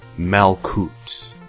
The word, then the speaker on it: Malkuth: Temple in Malkut. Spelled M-A-L-K-U-T-H. The H is silent.